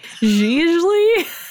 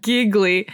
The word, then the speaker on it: Gigli: Gigli? Whatever it is. Okay. It's definitely not giggly. – It's spelled G-I-G-L-I. – It's definitely if like Italian.